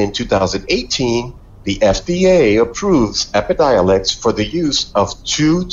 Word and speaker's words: Epidiolex: Epilidolex. – Epidiolex. – I was close.